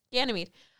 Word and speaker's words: Ganymede: Okay, Aquarius, this is the story of, I'm going to say this wrong, Ganymede? – That's what I was thinking. – Yeah, okay, Ganymede.